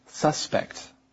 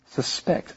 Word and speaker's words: suspect: But friends, something about this word is that when it's a noun like this, you would say suspect. But if it's a verb, you would put the emphasis on the second syllable, suspect. I suspect that you are a suspect.